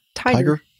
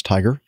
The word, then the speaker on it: TIGHAR: Gives us another link to the International Group for Historic Aircraft Recovery, which, how would you pronounce it? TIGAR? – Tiger. – Oh, okay. TIGHAR, that makes sense. Tiger,